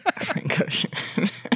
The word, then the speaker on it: Spa-Francorchamps: Spa-Francorchamps. – Spa-Francorchamps. – Close. – Francorchamps.